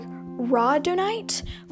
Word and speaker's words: rhodonite: Okay, so this next one, I've seen many people pronounce it different ways, but the most common way to pronounce it is, like, rhodonite. It's spelled rhodonite.